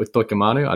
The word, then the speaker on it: Utoikamanu: Good news as well for Stefano Utoikamanu. I didn't want to say it. How would you rate that pronunciation, just quietly? – That's pretty good.